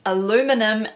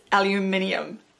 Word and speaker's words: aluminium: Yes, I know that it is pronounced aluminum in the United States, but to the rest of the world, including in the Antipodes, it is aluminium.